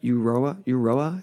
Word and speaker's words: Euroa: Although some of these banks were in pretty small places, but like in 1878, they held up one of the national banks at Eurora. Am I saying that right? Euroa in Victoria for around £2,000.